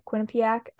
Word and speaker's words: Quinnipiac: Um, okay, Danielle Marmer was working with the Bruins last year as player development and scouting assistant, and she worked at Quinnipiac. I cannot pronounce that. I is probably wrong. – Is that what, right? Yeah, Quinnipiac's right.